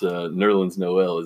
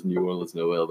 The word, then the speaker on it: Nerlens Noel: I wish we would have got to hear him pronounce Nerlens Noel as New Orleans Noel.